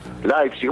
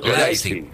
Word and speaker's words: Leipzig: Leipzig, ¿cómo se dice? Vos, Leipzig. Con A. – Leipzig, ahí está.